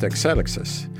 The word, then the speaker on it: Exelixis: And this company was Exelixis? – Yes. It's Exelixis, which is properly pronounced Exelixis. – Right? – It's a Greek word, and it means kind of evolution or forward progress, or an apt name for a biotech company.